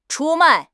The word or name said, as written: 出卖